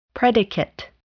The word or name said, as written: predicate